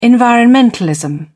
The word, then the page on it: environmentalism: British English